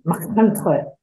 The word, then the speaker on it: Machynlleth: Yeah, we used to go and visit them once a year and Dad couldn't speak Welsh, but he used to pronounce all the Welsh place names in a really Welsh way. So places like Machynlleth, he used to say Machynlleth,